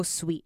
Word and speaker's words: sweet: 好，那看一下 so 对不对？so 其实它是 s 的音，so so oh oh so so sweet。好，那其实这样看 s 的发音是没问题，但是我们看到 sweet 后面这边。它这边其实是念 t，原本是 t，但是我们到这边我们把它念成短音，轻音就这样。 It is so sweet。 Sweet 就是点一下就好了。